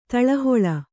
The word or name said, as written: ತಳಹೊಳ